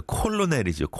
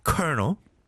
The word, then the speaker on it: colonel: C-O-L-O-N-E-L. 이걸 누가 colonel이라고 생각하겠어요? 콜로넬이죠, 콜로넬. 그죠? But you pronounce it colonel. 그 다음에 또 이제 R과 L의 조합은 참, 너무 힘들죠. Rural. Rural. 시골. R-U-R-A-L.